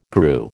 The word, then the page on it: grew: American English